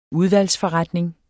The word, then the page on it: udvalgsforretning: [ ˈuðvals- ]; [ ˈuðvaljs- ]